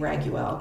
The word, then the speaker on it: Raguel: So I say Raggy so often that like before we did this episode, I had to practice saying Raguel because I was afraid I was going to say it wrong because it's spelled R-A-G-U-E-L, which is like weird to look at and pronounce.